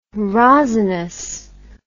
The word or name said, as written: rosinous